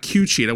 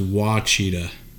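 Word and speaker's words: Ouachita: Yeah, it's O-Cheetah. It's not Q-Cheetah. Where'd you get the Q from? – I believe it's pronounced Wachita.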